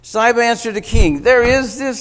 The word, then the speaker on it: Ziba: Now there was a servant of Saul's household named Ziba. It is Ziba. I looked at the self-pronouncing Bible. It's Ziba.